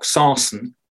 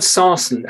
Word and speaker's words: sarsen: It's actually the largest megalith at Stonehenge, and it's made of a local rock, sarsen. – Which I learnt from your book may have derived from Saracen, the name sarsen. – Yes, that's interesting. That so a Wiltshire pronunciation of Saracen.